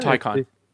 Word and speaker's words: Taycan: How do you pronounce it again? – Taycan. – Taycan? – Taycan. Like, yeah, Taycan. I mean, content.